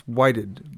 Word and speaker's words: Whitehead: I think it's Whited, but it's spelled Whitehead.